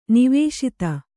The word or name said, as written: ನಿವೇಶಿತ